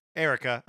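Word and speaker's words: Eirika: The Sacred Stones brought us, how would you say this? Erica? – Erica. Erica. Yeah. That extra I is in there just to mess you up.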